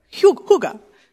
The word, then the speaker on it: hygge: Lara got me onto this book called I forgot to check the pronunciation. It's Danish. Holy Hygge. Hygge. It's a Danish word. It means kind of like intentional, thoughtful coziness. Is that close? Pretty good.